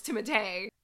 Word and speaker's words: Timothée: Well, anyways, now he's even hotter because his name is pronounced Timothée.